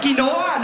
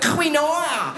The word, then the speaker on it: Quinoa: Ich sehe eine Mutter, die mit ihrem Kind schimpft und Quinoa falsch gesagt hat. Es heisst Quinoa, nicht Quinoa.